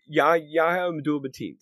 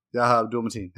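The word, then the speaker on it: Yahya Abdul-Mateen: Yahya Abdul Mateen. Say it fast, you can't say it wrong. – Yahya Abdul Mateen.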